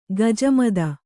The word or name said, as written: ಖಿಜಮತ್ತು